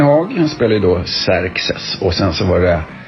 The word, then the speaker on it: Xerxes: Jag vill bara säga att eh, man uttalar ju Xerxes då.